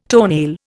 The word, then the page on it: toenail: Indian